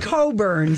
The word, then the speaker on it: Cockburn: But it's pronounced Coburn. Excuse me. But is it spelled C-O-C-H? – C-O-C-K-B-U-R-N. Boy, that must have been a hellish name growing up.